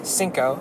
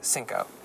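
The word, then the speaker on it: Cinko: My handle is Anthony X Cinco, and that's C-I-N-K-O.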